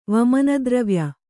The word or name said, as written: ವಮನದ್ರವ್ಯ